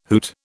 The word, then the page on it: hoot: British English